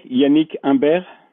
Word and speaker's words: Yannick Imbert: Yannick Imbert.